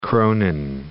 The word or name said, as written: kronen